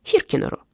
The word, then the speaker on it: Хиркинер: И вы попробуете уговорить ее продать ее долю этому Хиркинеру. Как произносится, так и пишется.